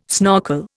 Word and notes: snorkel: Indian

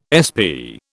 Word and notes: SP: British English

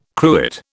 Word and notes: cruet: British English

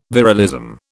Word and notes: virilism: British English

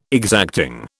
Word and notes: exacting: British English

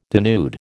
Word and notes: denude: American English

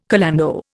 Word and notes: calando: Indian

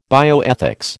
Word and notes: bioethics: American English

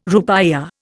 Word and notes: rupiah: Indian

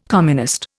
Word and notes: communist: Indian

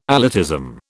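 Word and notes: élitism: British English

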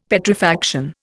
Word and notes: Petrifaction: Indian

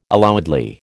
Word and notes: allowedly: American English

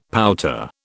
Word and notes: pouter: British English

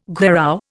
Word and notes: gherao: Indian

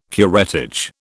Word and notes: curettage: British English

